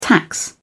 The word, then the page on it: tax: British English